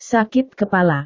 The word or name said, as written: sakit kepala